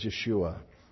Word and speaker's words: Yeshua: We formed it into Jesus, but the Hebrew pronunciation is Yeshua.